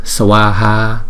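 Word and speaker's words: Swaha (स्वाहा): Swaha, swaha. Thấy không? Thì chúng ta đọc hai chữ A này là hai nhịp.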